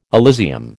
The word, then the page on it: Elysium: American English